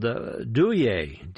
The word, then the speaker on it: Douye: Right now, though, let's do one from a singer called Douye, D-O-U-Y-E, with an accent on the E. Douye, I guess, maybe.